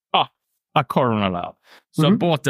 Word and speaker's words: Coroner: Coroner.